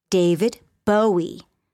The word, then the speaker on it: Bowie: She pronounced winner David Bowie's name incorrectly, using the ow sound, as in the word how, rather than the O sound, as in the word no, David Bowie.